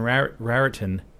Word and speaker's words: Raritan: I don't know, when I say the name, it's Raritan, it's R-A-R-I-T-A-N, Raritan, but I always say Raritan. You say it like in Raritan, Raritan, like that's how you pronounce it, Raritan. But why not just say Raritan, Raritan, you know?